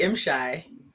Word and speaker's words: M'Shai: It's actually M-Shy.